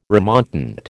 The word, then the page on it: remontant: American English